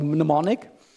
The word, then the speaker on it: mnemonic: I don't know why they call it that, but it's called mnemonic. and the mnemonic. The M is silent.